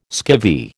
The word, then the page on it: skivvy: American English